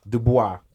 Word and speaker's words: Du Bois: Du Bois, or Du Bois, never knew how to say that right, research. – Du Bois. Thank you.